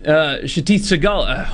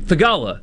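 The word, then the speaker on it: Theegala: Satith Thagala. Oh my gosh, I butchered his name. Thagala. Satith Thagala is back.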